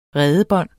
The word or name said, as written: redebon